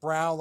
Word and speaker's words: Braun: Yeah, but it's pronounced Brown like the color, B-R-O-W-N, but it's spelled B-R-A-U-N. – Oh, okay, like Braun. – Braun, but we say Brown. – Gotcha. – Yeah, that's how it's pronounced.